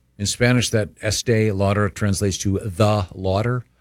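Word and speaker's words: Estée Lauder: Is it pronounced Estee Lauder? – Christine? Estee Lauder, yes.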